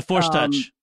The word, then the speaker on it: Force Touch: The Force Touch. – Force Touch.